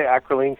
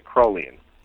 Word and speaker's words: acrolein: Is it, and I may get the pronunciation wrong, acrolein? – Some people say acrolein, some people say acrolein.